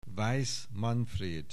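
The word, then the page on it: Weiss, Manfréd: Hungarian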